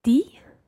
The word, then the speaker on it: dit: J'ai dit. – Dit, exactly. It rhymes in a sense with écrit and also spelt the same way, D-I-T.